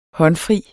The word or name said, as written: håndfri